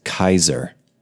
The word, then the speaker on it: Caesar: Also, did you know that, like Caesar salad or Caesar Augustus, if it's spelled with a C, even though it's spelled with a C, it's actually pronounced Kaiser, like Kaiser Wilhelm.